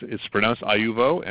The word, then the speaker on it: IUVO: IUVO Technologies, right? That's your company, right? – Yeah. It's pronounced IUVO, and it's a IUVO. – Latin word.